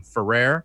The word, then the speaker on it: Ferrer: And then Preston gets his bike run over by this like jerk in a suit played by Miguel Ferrer. I've never really known how to say his last name. Ferrer? Miguel Ferrer? – That sounds right.